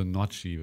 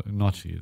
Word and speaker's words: Gnocchi: Äh, oder nein, man spricht das G nicht. Also Nocci, würde ich sagen. Also Nocci.